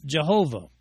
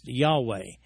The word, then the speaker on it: Yahweh: The name of the Lord in that passage is the special name for the God of the Jews, Jehovah, otherwise pronounced Yahweh.